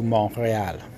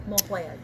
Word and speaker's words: Mont-Royal: Avenue Mont-Royal. Mont-Royal, yes.